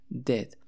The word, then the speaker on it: did: Entonces, la primera es el verbo do, pero en pasado que se escribe did, pero se pronuncia como si la I fuera una E. Es decir, lo pronunciamos dead.